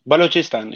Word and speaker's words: Balochistan: I was going to say, so you were born in Pakistan, and you were born, how do you say it? Balakistan? – Balochistan, yeah.